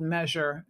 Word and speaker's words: Mezr: And if you don't know your body type, we have a really handy app that you can download from the App Store called Measure. It's spelled M-E-Z-R.